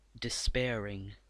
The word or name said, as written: despairing